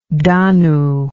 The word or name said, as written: danu